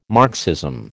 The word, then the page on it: Marxism: American English